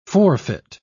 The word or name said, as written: forfeit